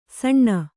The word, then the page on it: ಸಣ್ಣ: Kannada